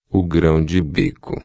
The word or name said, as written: grao-de-bico